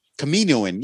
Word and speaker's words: Kaminoan: Com- how do you say it? Camoan, Kaminoan, Camoan. – Um, you, yeah, a lot of it Kaminoan. Yeah, there you go.